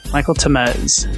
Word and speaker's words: Michael Tamez: Michael Temez.